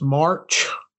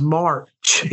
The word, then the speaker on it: March: I just want to say that my real last name is March. Stan's just been saying it wrong for years. He's never said my name right. – Your last name is Marsh, the same as the little South Park character, pal. – No, it's March, M-A-R-C-H.